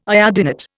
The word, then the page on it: iodinate: Indian